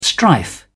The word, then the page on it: strife: British English